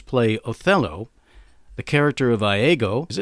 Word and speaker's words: Iago: I was looking it up on the internet, and I did find out that the British pronunciation, which this is Shakespeare we're talking about, is Iago. So there you go, Iago.